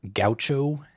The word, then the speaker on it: Gaucho: Gaucho, is that how you say it? – Yeah.